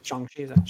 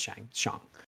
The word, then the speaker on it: Shang-Chi: Shang Chi, Shang Chi, not Shang, Shang.